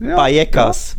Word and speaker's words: Vallecas: Und da siehst du vor allem hier beflügelte Basken in Vallecas, wenn ich es jetzt spanisch korrekt ausspreche, mit eher B statt V.